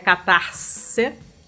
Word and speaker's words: Catarse: que eu já aprendi agora que é a pronúncia correta. Eu nunca tive certeza do que eu tava falando, agora eu sei. É Catarse.